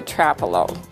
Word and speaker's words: Trapello: I won't give someone a hard time if they say Trapello, but I will politely correct them to say it's actually Trapello.